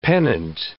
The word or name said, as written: pennant